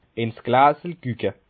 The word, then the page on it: in's Gläsel gücke: Bas Rhin; Schiltigheim